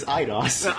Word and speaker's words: Eidos: So, I mean, just for me, John, my familiarity with the Uncharted series, at least originally under Eidos Interactive, which, just to point out, what are you laughing at? – Well, first off, it is Eidos.